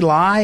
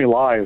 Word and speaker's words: Lai: The news out of Hong Kong today was a 71-year-old Jimmy Lai. Is that how you say it? L-A-I, Jimmy Lai, a business tycoon arrested.